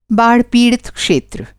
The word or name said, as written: बाढ़-पीड़ित